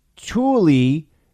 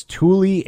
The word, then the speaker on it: Thule: Keith writes, it's Thule and rhymes with school plus E. Oh, it's Thule Air Base, huh?